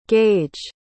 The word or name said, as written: Gaige